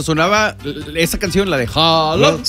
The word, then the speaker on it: Haaland: Que me dicen que no se pronuncia Haaland, es Holland. – Holland. – Holland. Nosotros le decimos Haaland porque viene sí. Pero es Holland.